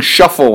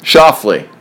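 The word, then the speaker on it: Schauffele: What's the correct pronunciation of his last name? I can't get it right. I keep seeing Shuffle when I see his name, and I know that's not right. What is the correct pronunciation of his last name? – Shoffley. – There we go.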